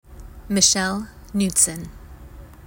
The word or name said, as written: Michelle Knudsen